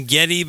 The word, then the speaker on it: Geddy: Yeah, the thing, isn't his name Geddy because that's the way his mother pronounced his name with her accent? – Yeah, the way she pronounced Gary came out as Geddy.